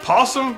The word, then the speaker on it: opossum: First, we got this thing called a possum, although it's spelled opossum, but the O is silent because English.